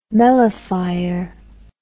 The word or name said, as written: melaphyre